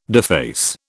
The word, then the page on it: deface: British English